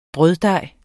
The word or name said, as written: brøddej